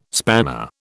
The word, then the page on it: spanner: British English